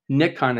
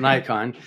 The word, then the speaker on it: Nikon: So I'm interested at the outset here, talking about how you went from doing your work with Nikon, as you pronounce it over here in America, we call it Nikon.